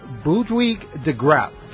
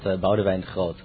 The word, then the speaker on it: Boudewijn de Groot: The song is called Jimmy, and the band, I'm going to try to pronounce it, and then you're going to pronounce it correctly, and we'll see how badly I got it. Boudewijk de Groot. – Well, it's an artist, actually. It's called Boudewijn Groot. – See, that's not even similar at all.